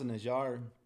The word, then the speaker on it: Najjar: So they say it's it's pronounced Najjar. Like I was saying, it it it's supposed to be carpenter.